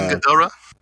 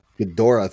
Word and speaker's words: Ghidorah: Now you said Ghidorah. I never say his name. – King Ghidorah. – Ghidorah, thank you.